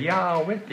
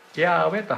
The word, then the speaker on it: ヤーウェ: で、これヘブライ語でヤーウェと言います。ヤーウェと発音します。